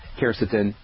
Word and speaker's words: quercetin: There's a bioflavonoid actually that's really has a lot of literature that supports its use as an antihistamine, and that is something called quercetin, and that's spelled Q-U-E-R-C-E-T-I-N, quercetin.